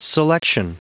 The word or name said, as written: selection